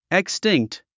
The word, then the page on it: extinct: ikstíŋkt; イクスチィンクト